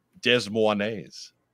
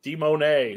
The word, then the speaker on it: Des Moines: It's Des Moines. – Des Moines. – Oh. – De Monet.